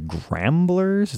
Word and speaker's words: Gramblers: Nicki Bluhm, Tim Bluhm, one of the Mother Hips, one of the is it the Gramblers? Is that how you yes. pronounce that? Yes.